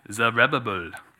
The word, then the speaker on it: Zerubbabel: Zerubbabel.